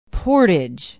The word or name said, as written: portage